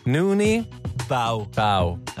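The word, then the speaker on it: Noonie Bao: Nuno Bao. Du klarer ikke uttale. Er det... – Ja, jeg synes det litt for voldsomt. – Nuni Bao. – No Bao. – Nei, Nuni. – Nuni. – Bao.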